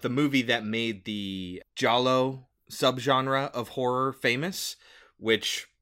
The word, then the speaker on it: giallo: I thought you pronounced that totally differently. – Giallo. – I did not think that was how you did it. – Yeah. Gialli is the... – It's with a G, right? – Yeah.